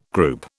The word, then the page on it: group: British English